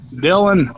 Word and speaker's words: Dillon: Did you say Dillon or Fillon? – Dillon. Dillon with the D.